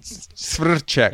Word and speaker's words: Svrček: Second was Sudal Quick Steps, Slovakian pronunciation, Martin Svrček, I think. – Svrček.